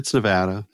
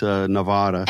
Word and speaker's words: Nevada: No, it's Nevada. – I've been saying it right. – Yeah, no, it's only people from the East Coast that call it Nevada.